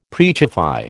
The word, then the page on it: preachify: American English